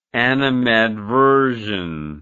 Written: animadversional

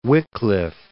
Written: Wycliffe

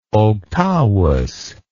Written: octavus

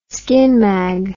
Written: skinmag

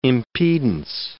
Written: impedance

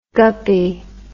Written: guppie

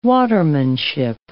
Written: watermanship

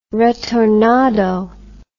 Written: retornado

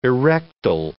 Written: erectile

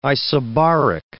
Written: isobaric

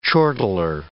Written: chortler

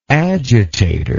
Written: agitatorial

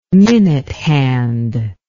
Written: minute hand

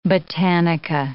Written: botanica

